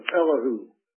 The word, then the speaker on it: Elihu: This man, Elihu, or as some pronounce it, Elihu.